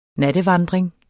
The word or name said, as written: nattevandring